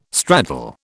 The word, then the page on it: straddle: British English